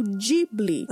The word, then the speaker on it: Ghibli: E com seu sucesso, Akuma o estúdio Ghibli, que Miyazaki pronunciou Dibli, palavra em italiano para vento seco do Saara, bem como o nome de uma aeronave da Primeira Guerra Mundial.